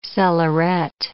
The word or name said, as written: cellarette